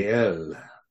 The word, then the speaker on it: Gael: Gale. The accent is on the last part.